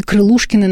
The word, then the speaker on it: Крылушкин: Его зовут Сила Крылушкин. Или Крылушкин, иногда вот слышишь такое, такое ударение.